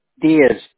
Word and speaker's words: Dias: Diaz. D-I-A-S. – Got it. D-I-A-S.